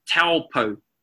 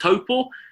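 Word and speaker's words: Taupō: Okay, think of the word taupo. That is the AU sound in the word taupo. A lot of times people mispronounce it and it comes out as an owl. Okay, but it's actually pronounced like the letter O. O. Okay, so we've got A, U. When you say it together, it comes out as O.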